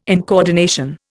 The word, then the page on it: incoordination: Indian